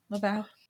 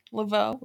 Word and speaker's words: Laveau: in hope that the Laveau spirit, Laveau, I was saying it right the entire time. Yeah, Laveau.